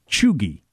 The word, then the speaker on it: cheugy: You pronounce it chugi. Chugi.